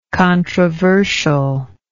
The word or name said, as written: controversialist